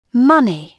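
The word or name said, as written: money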